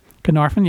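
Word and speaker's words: Caernarfon: Carnarvon, yeah.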